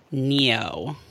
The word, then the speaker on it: Nioh: I've been playing Neo, and it's something. – Neo. Is that the game that I have seen people talking about? It's spelled N-I-O-H.